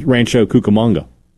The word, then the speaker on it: Rancho Cucamonga: I know how to say this because there's a couple of famous wrestlers from that city. – Okay. – It's Rancho Cucamonga.